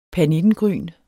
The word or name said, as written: PERNITTENGRYNEN